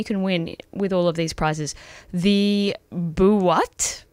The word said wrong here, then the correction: Boite: Yep. – Is that how you pronounce it? – The Boite. – The Boite. Yep. Double pass to a selected gig.